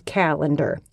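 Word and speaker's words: Callandor: Okay, the sword is named Calendar. – Can you spell that for us? – Calendar. C-A-L-E-N-D-A-R. – Good. – Obviously. – Good. – Like a calendar.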